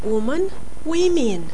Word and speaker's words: women: Deci orice derivat cu men este, în scriere este m-a-n la singular și m-e-n la plural. Un alt substantiv cu aceleași terminații la singular și la plural, însă de data aceasta cu pronunție diferită pentru singular față pentru plural față de singular, este woman, femeie. Woman, women.